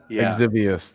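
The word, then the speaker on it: Exivious: And the ex-Vixius. – Yeah, and then Nate over here showed me Exivius. – Exivius, there you go. I added an extra syllable in there.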